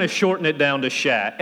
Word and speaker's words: sha: The next word that we have is sha, S-H-A, sha. The French word, which is the same word, is cher, C-H-E-R.